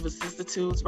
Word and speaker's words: vicissitudes: okay, so did I pronounce vicissitudes right? Yes, perfect.